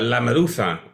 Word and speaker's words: LaMeduza: I was wondering if I should sort of use Spanish pronunciation like LaMeduza, or should I just be... Is that right? Okay. I mean, that's how I would pronounce it, but I'm not sure, like... – Yeah, LaMeduza, that's pretty much it, LaMeduza.